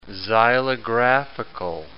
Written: xylographical

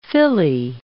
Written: filly

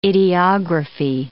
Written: ideography

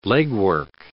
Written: legwork

